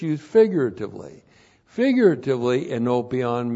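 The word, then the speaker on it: Enopion (ἐνώπιον): And then we're going to have enopion two times here. Enopion. E-N, long O-P-I-O-N. Enopion.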